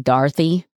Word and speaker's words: Dorothy: Dorothy, and I really should pronounce her name Dorothy with a sort of A sound, because that's how she pronounced it.